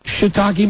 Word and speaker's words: shiitake: I, for the longest time, thought when I didn't know anything about food that there was a different pronunciation with those. The second part being take. – Yes, that's right. – I actually said that in a restaurant. – It's shiitake.